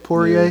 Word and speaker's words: Poirier: That's how you say it, Poirier.